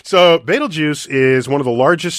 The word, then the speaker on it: Betelgeuse: And that's how you pronounce it, Betelgeuse.